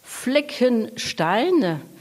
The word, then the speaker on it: Fleckenstein: Alors, le nom du château, je vous laisse le prononcer. Vous allez me prononcer beaucoup mieux que moi. – Fleckenstein. Donc Fleckenstein,